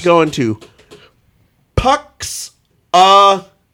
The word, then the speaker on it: Punxsutawney: He's a very full of himself Pennsylvania weatherman who gets trapped, stuck having to go to Punxsutawney. – I don't think you said that right. – No, let's try that again. – Okay. – Who gets stuck going to Punxsutawney, Pennsylvania.